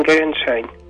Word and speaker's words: Gegenschein: El Gegenschein, que lo escuchamos en la cápsula 2, es un contrabrillo, pero voy a dejar que tú lo pronuncies, porque tú pronuncias esto mejor que yo al alemán. – Bueno, lo dijiste bien, Gegenschein.